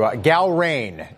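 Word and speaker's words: Gallrein: Galron is the pronunciation.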